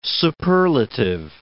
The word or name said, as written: superlative